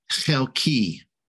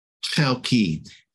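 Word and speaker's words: chelki (חלקי): And the Hebrew word, you can see it over on the right-hand side, it's in the Hebrew, is the Hebrew word chelki, chelki. And it's spelled Chet, Lamed, Kuf, and Yod. The Yod at the end there actually is denotes it's a suffix, and it means my.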